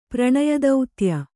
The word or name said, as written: ಪ್ರಣಯದೌತ್ಯ